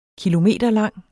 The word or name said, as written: kilometerlang